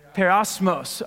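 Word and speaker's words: Periosmos (πειρασμός): Periosmos.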